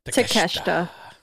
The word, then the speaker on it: Takeshita: Say his name right because I always say it wrong. – Takeshita. – Thank you.